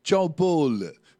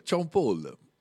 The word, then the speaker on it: Sean Paul: Si legge Sean Paul. È vero, Sean Paul.